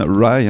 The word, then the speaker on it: Rhi: Oui, la canadienne, mais à présent londonienne, Rye, hein, ça s'écrit R-H-I, qui débute son nouvel album The Pale Queen en chantant I'm a grunger, grunger, grunger Baby.